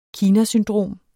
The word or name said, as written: kinasyndrom